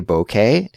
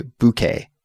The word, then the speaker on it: bouquet: Cassie, I also noticed that we pronounce bouquet differently. I say bouquet and you say bouquet. – Yeah. – So that's interesting. That's another flower-related word where there is some variation in the pronunciation. Interesting. Bouquet, bouquet, both are okay.